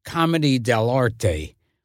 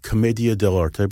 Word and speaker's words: Commedia dell'arte: Comedy dell'arte. – I pronounce it Commedia dell'arte, but that's good.